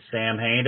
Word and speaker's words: Samhain: Is it is it actually Sam Hain, or is or is it like the old Halloween version that like they talk about Halloween movie, the Samhain? – Yeah, well, that's the that's the correct uh, pronunciation, but we always figured it was too confusing for people, so we just went with the um the spelling, I guess.